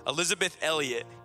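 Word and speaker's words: Elisabeth Elliot: Sorry, I said her name like with six syllables. Elizabeth Elliot.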